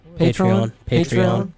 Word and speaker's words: Patreon: But they got it done against UCF in the Military Bowl presented by Patreon. I think. Is that how you pronounce it? – Patreon. – Oh, I got it right.